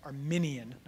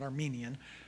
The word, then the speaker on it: Arminian: This is not a Calvinist versus Arminian, or how do we say that? Arminian, not Armenian. That's a country.